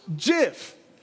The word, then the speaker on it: GIF: The actual inventor of JIF says it's pronounced JIF.